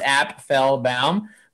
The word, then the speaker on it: Apfelbaum: because some people have a hard time pronouncing my last name. It's actually pronounced Apfelbaum.